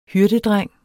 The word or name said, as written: hyrdedreng